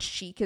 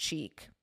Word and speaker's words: chic: In Farsi, the word chic is pronounced cheek. Also, it's chic, and you would pronounce it chic. But so I think maybe knowing that and seeing it written, she just assumed in English it was pronounced chic.